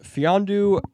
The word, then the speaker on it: Mfiondu: So to move into the first round to grab Fiondu Cabangeli. It is spelled M-F-I-O-N-D-U. The M is silent, which is a very...